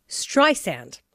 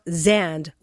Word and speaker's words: Streisand: It's Streisand. Or she says, like sand on the beach, not Zand with a Z.